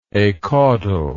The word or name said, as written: acaudal